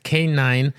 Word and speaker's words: K9: en, la historia, eh, esto de usar eh, perros caninos, eh, K9, que le dicen en inglés K9, literal canino, pero se escribe C-A-N, no, pero pone K9 porque se escucha igual, Entonces ahí viene el nombre ah, K9.